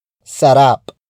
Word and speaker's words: sarap: Sarap is spelled as S-A-R-A-P. As an adjective, it means delicious. Again, note how I elongate the stressed syllable in the sentence for added emphasis, which in this case is rap.